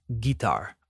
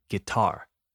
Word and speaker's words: guitar: The first one guitar. I hear guitar a lot, but that's not how we say it. We say guitar with the emphasis on the second syllable guitar.